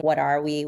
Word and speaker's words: Watawi: Number 19 is a song, Watawi, which is really, it's spelled W-A-T-A-W-I, but it's really saying, what are we, with like an accent, essentially.